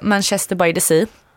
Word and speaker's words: Manchester by the Sea: Och sen så skrev jag Manchester by the Sea. Uttalar jag rätt nu? – Ja.